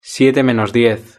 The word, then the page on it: siete menos diez: European Spanish